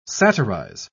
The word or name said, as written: satirize